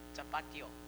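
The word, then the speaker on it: Tapatío: Tapatio.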